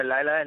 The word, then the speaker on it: Lila: She goes by Lila and Lila.